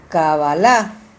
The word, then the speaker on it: Cabalá: Recuerda, Cabalá con K y acento en la última A, Cabalá, y no Cábalas.